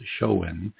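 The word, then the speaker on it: Samhain: Well, the holiday traces its origins back to 2,000 years ago to the ancient Celtic festival of Shaham, pronounced Shoen, celebrated from October 31st to November the 1st.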